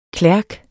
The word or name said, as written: klerk